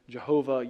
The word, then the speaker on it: Yahweh: Your Bible may have it capital L-O-R-D. That is signifying that is the word Yahweh. There's disagreement about how to pronounce it, Jehovah, Yahweh. It's most likely the word Yahweh.